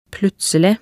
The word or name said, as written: plutselig